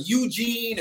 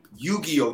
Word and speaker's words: Yu-Gi-Oh!: It was Eugene and Yugaya. No one could pronounce the name right. And then one day I'm in a grocery store and it's on the cover of TV Guide. Yu-Gi-Oh!